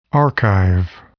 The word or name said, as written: archive